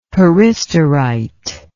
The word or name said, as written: peristerite